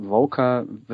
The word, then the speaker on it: Vocer: Also Voca wird tatsächlich mit K ausgesprochen, obwohl es sich V-O-C-E-R schreibt.